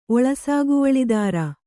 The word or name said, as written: ಒಳಸಾಗುವಳಿದಾರ